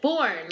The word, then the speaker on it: Bourn: Bourn.